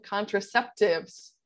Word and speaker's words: contraceptive: Am I saying that right? – You're right. Contraceptive.